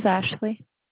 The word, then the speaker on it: Ashley: My name is Ashley. – How to spell it? – A-S-H-L-E-Y. – A-F-S? – L-E-Y.